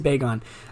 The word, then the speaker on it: Bagon: Uh, the correct pronunciation is Bagon, according to the Pokemon community.